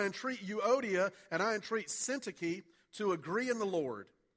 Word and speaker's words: Syntyche: We called Dr. Horn, who's Greek and Hebrew professor up at North Greenville, and said, "Hey, how do we pronounce this? Because I think it's like Syntyche or something." And he said it's Syntyche. So I know it's right.